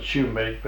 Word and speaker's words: sumac: They call it shoemake, but it's spelled S-U-M-A-C.